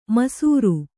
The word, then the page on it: ಮೈಸಿರಿ: kannada